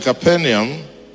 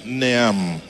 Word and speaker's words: Capernaum: So he dwelt in Capernaum. It's an A-U-M, Neam. Okay, you see that? You see, we have to teach everything, including pronunciations. Yeah, otherwise, Neam, I don't see an I, no.